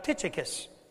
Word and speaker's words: Tychicus: And one of those true friends was this man by the name of Tychicus. Now, I didn't just sneeze, and Reggie didn't sneeze. We've talked about how to pronounce this, and so anyway, but Tychicus was a true friend of the Apostle Paul.